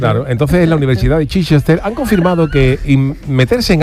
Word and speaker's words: Chichester: Eso sería, se pronunciará Chichester o algo así, ¿no? – Chichester, claro.